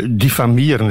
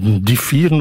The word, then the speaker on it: diffamieren: Hitler gebruikte graag het woord diffamieren. Hij zei het anders. Diffieren of diffieren. Hij kon het niet goed uitspreken.